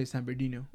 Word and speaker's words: San Bernardino: You're from San Bernardino, you say San Bernardino.